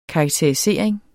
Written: karakterisering

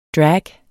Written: drag

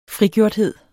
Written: frigjorthed

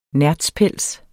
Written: nertzpels

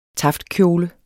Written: taftkjole

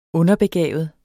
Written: underbegavet

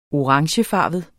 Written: orangefarvet

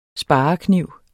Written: sparekniv

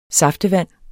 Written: saftevand

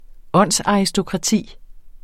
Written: åndsaristokrati

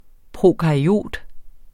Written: prokaryot

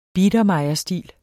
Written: biedermeierstil

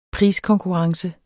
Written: priskonkurrence

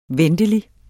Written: ventelig

